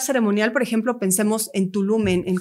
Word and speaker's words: Tulum: Es que realmente no, Tulum. Es Tulum.